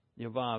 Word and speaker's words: Yahweh: Yahweh meaning Jehovah, but really the proper pronunciation.